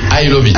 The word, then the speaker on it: ILOVEAT: C'est Ilovitz. Alors, ça se prononce en anglais, mais c'est écrit I-L-O-V-E-A-T. Donc, I-L-O-V-E-A-T.